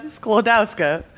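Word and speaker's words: Skłodowska: I believe that is the correct pronunciation where you go up at the end. – Yeah. Sklodowska? Sure, very thick accent. Maria Salomea Sklodowska.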